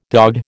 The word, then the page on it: dogged: American English